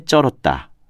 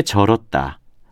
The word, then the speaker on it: 절었다: 온몸이 땀에 쩔었다 또는 그는 술에 쩔어 산다와 같이 된소리로 발음하는 경우가 많은데 이 말은 절다에서 나온 것이기 때문에 땀에 절었다, 술에 절어 산다와 같이 예사 소리로 발음하는 것이 맞습니다.